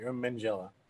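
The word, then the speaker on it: Minghella: I want to say Mangella, but I don't think that. – I think it Mangella. You're right, Mangella.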